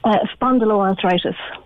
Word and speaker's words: spondyloarthritis: Spondyloarthritis.